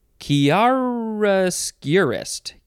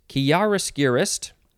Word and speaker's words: chiaroscurist: How many ways can you pronounce this? Let us count. You can say chiaroscurist. Chiaroscurist. Whatever. I'm not going to count them. Chiaroscurist. Yeah, you get the idea. Chiaroscurist. What? Yeah. It's spelled C-H-I-A-R-O-S-C-U-R-I-S-T.